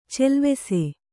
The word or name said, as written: ಚೆಲ್ವೆಸೆ